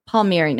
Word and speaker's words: Palmieri: And today with me is Kevin Palmieri.